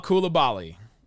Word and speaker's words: Coulibaly: Number 12, give me the guy whose name we finally learned how to pronounce, Bilal Coulibaly.